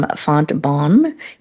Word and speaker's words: Fontbonne: She received her undergraduate in business administration from St. Louis University, an MBA from Fontbonne University.